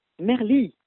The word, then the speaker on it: Merly: Chemin de Merle ? – Merli. – Ah, de Merli. – C'est vrai que ça aurait pu être Merle. – Ouais, oui, c'était rigolo. Merli, IE, comment ça s'écrit? – Y, d'accord. – M-E-R-L-Y.